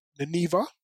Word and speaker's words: Nineveh: There's a city called, am I pronouncing it correctly? Nineveh. Yep.